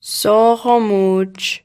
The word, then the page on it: so'qomu'j: Mi'gmaq